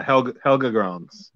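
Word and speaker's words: Helge Grans: and I'm not sure how to say his name, Helge Granz, is that right? – Yeah, Helge Granz.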